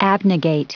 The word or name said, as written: abnegate